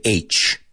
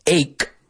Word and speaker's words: ache: I've heard a lot of people mispronounce this word and say H. You know, I have a head H, I have a stomach H. No, H is H. Ache. No, apúntalo en tus apuntes como E-I-K, ache, right?